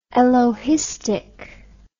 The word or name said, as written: elohistic